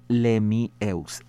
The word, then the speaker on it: Lemieux: Marie-Nicole, ah, eh, como, como se escribe, como suena, Marie-Nicole y luego Lemieux, e e L-E-M-I-E-U-X, Lemieux que,